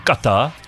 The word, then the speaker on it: Qatar: It's pronounced Qatar. – Say it. – Qatar? – Qatar.